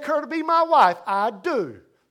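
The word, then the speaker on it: I do (ἰδού): The Greek word for the word behold is a word spelled I-D-O-U. And it's pronounced I do.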